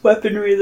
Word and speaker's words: weaponry: Just weaponry. There's no D.